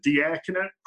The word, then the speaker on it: diaconate: There's a lot of ways to give back, and without having to spend 5 years in a, what is it, diaconate program? Did I say that right? – Diaconate formation program. That's right.